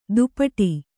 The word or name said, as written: ದುಪಟಿ